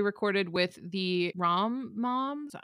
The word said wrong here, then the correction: Mom-Coms: I'm sorry. Am I saying that correctly? – Mom-coms. – Thank you. The mom-coms. Yes.